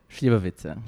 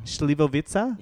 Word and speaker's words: Slivovica: taking a, a shot of Let's pronounce it properly. Slivovica. Slivovica? Yeah, not bad.